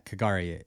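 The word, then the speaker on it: K'gari: Yeah, Kigari.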